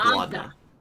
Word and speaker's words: Laudna: That is a good question, and I will not answer that as Doon for a I think it has to be Launda. Mm-hmm. Am I saying it right? Launda? – Yeah, Launda. Or yeah. Laudna? Laudna. – Laudna. – Is it Laudna or Launda? No, I think it's Laudna.